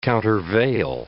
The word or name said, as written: countervail